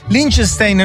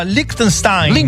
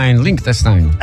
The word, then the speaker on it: Liechtenstein: Liechtenstein non si può sentire, si pronuncia Liechtenstein. – Liechtenstein.